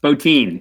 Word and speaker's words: Bottin: Bottin. It's pronounced Bottin.